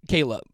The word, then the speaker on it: Kierla: Kayla. Yeah, it's spelled K-I-E-R-L-A, but apparently it's pronounced Kayla.